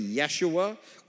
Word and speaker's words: Yeshua: The word Joshua is literally Yeshua, which is the Hebrew pronunciation of the name Jesus.